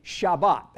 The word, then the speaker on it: Shabbat: Now, if we transliterate that, that means we put it in English, it's S-H-A-B-O-T-H, Shabbat. That's the way it's pronounced. Those of us in the Delta, Sabbath. That communicates to me. What about you? Sabbath. But Hebrews say Shabbat.